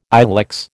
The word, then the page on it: ilex: American English